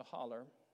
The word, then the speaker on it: hollow: But if a car came driving up the holler, H-O-L-L-O-W, properly pronounced holler, we saw a clean car, Buick, coming up the road.